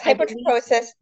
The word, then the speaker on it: hypertrichosis: Yes, hypertrichosis.